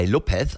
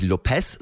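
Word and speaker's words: López: Daniel Lopez, sag mir. Und es ist immer wichtig, wenn ihr Daniel Lopez sagt, dass ihr bei Lopez, also am Ende, da spricht man nicht Lopez, sondern Lopez. Ganz, ganz wichtig.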